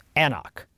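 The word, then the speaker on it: An Cnoc: I'm going to spell this for you because this is the Gaelic and this is where it's very hard to pronounce and sometimes hard to read. It's spelled lowercase A-N, same word, capital C, N-O-C, Annock.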